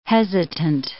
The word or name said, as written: hesitant